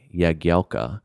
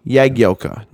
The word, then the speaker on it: Jagielka: I just think that there's a lot of times this feels like a very American way to say Jagielka. – Take that, NBC English pronunciation guide. Suck one. So. Jagielka. Jagielka. No ja. No ja. – Not ja.